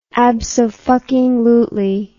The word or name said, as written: absofuckinglutely